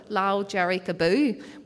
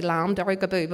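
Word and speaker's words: Lámh Dhearg Abú: Lao Jerry Kaboo. Well, they say Lam Jerry Kaboo, but we're trying to teach them how to say it properly.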